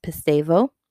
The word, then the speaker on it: pisteuo: And it is spelled P-I-S-T-E-U-O, in case you are someone that likes to take notes. And it's pronounced pisteuo. This word means to entrust.